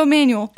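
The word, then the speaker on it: Amanuel: How do you spell it? – Emmanuel. It's A-M-A-N-U-E-L. So Emmanuel.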